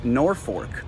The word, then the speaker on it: Norfolk: Well, in Massachusetts, that gives us Norfolk.